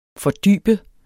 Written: fordybe

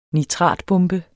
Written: nitratbombe

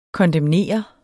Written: kondemnere

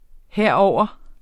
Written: herover

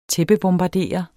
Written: tæppebombardere

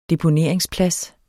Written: DEPONERINGSPLADSEN

